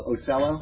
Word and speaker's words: Ocello: It's O-C-E-L-L-O. Ocello? – Ocello. – I was thinking maybe Italian Ocello.